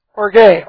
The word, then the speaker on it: orge: The Greek word for anger is orge, O-R-G long E.